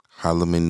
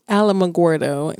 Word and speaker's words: Alamogordo: So we got orders to Alamogordo, New Mexico. – Well, it's actually Alamogordo, New Mexico.